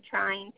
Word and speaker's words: Trine: It's Carla, C-A-R-L-A, last name Trine, T-R-I-N-E.